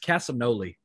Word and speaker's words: Castagnoli: It's Castagnoli, by the way. – Castagnoli.